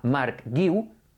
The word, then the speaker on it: Marc Guiu: Si le añades una U, guiu, ¿de acuerdo? Si la joven promesa del Barça se llama Marc Guiu, es exactamente igual de fácil de pronunciar que en castellano.